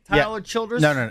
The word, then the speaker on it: Childers: Tyler Childers? – Yes.